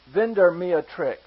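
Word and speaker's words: Vindemiatrix: and here's the star that is a little bit hard to pronounce. The name of the star is Vindermeatrix. If you want to jot that down, I don't know if you'll ever have an opportunity to use it, but it's Vindermiatrix.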